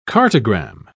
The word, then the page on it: cartogram: British English